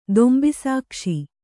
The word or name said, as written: ದೊಂಬಿಸಾಕ್ಷಿ